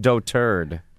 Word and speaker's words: dotard: And dotard is actually pronounced doturd.